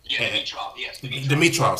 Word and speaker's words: Dimitrov: And Dimitrov, if I'm saying that correctly, Gregor Dimitrov, is ranked number 21. – Dimitrov, excuse me.